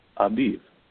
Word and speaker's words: Aviv: So you might, in your Bibles, you may see A-B-I-B, and some may pronounce it A-V-I-V. The B and V are actually the same letter in Hebrew, and so Aviv is how many pronounce it.